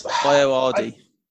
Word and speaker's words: Ayoade: Seems Ayoade.